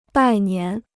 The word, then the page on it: 拜年: bài nián